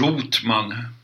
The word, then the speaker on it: Rothman: Först av allt, hur vill du uttala ditt namn? Rotman eller Rotman? Eh, Rotman, eftersom vi befinner oss i Sverige. Ja. – Inte tyskt uttal? – Jag avskyr Rothman därför att det har en tysk anstrykning.